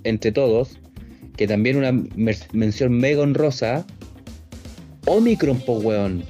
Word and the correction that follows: Unicron: Omicron. Unicron. Oh, Unicron, puta. siempre, eh, también, siempre, siempre lo digo mal, weón.